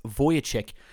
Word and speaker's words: Vujicic: My name is Nick Vujicic, pronounced Vujicic.